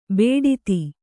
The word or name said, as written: ಬೇಡಿತಿ